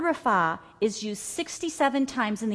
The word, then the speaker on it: Rapha: Let me tell you, that word healed is the word rapha.